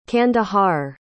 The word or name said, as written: Kandahar